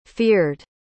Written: feared

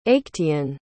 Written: actaeon

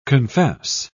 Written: confess

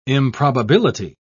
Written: improbability

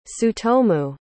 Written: tsutomu

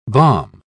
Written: balm

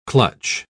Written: clutch

